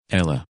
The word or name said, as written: Ella